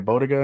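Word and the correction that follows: Bogotá: in Colombia. It starts Bogota. Like there. Yeah, Bogota in that line.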